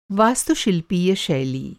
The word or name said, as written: वास्तुशिल्पीय